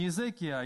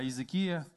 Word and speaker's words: Иезекия: Не Неемия, не Иемия, не Иеремия, а Иеремия, не Иезекия, а Иезекия.